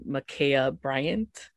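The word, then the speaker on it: Ma'Khia Bryant: And I think if we look at what happened to, I hope I don't butcher her name, Makaya Bryant, that's how you pronounce her name.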